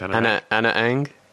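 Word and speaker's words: Ana Ng: He chose Anna Ang. Is it pronounced Anna Ang? Yeah, Anna Ang.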